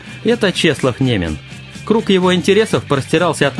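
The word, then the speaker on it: Niemen: Псевдоним Немен взял в честь реки Неман в польском произношении, на которой жил в детстве, хотя от того места, где он жил, до Немана около 40 километров.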